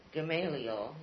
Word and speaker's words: Gamaliel: W-A-I-T, or W-A-I-T was used. The earliest settlers of the name in New England were Richard Gamaliel, is that the way you pronounce it? – Yes.